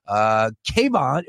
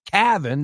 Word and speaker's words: Cavan: Kayvon, how do you say his first name? – Cavan. – There Cavan. You go. It'd be cooler if it was Kayvon.